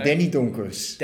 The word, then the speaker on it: Danny Donkers: How I would pronounce that in Afrikaans. I don't know how that sounds in Dutch, Danny. – Very similar, Herman. Very similar. Okay. Danny Donkers.